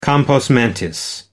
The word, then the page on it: compos mentis: American English